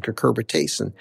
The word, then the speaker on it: cucurbitacin: There's a compound, it's a natural compound that all squash have, cucumbers have it as well, called cucurbitacin.